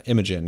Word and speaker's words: Imogen: Is it Imogen or Imogen? Heap. She's Imogen? – Done, yeah, Imogen.